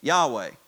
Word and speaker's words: Yahweh: In the Old Testament, his name was Yahweh. Yahweh, that's how it's pronounced.